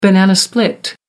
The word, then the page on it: banana split: American English